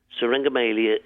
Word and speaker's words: Syringomyelia: I don't even know how to pronounce it. Syringomyelia? – Syringomyelia.